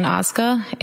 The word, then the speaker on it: Ozga: It's Allison Oizuka, A-L-L-I-S-O-N. Last name is O-Z-G-A.